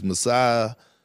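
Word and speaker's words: Masai: I always have problems pronouncing his name, but it's Masiah Ujiri.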